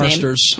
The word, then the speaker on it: Marsters: I mean, Anthony Stewart Head, James Marsters, how do you say his Marsters.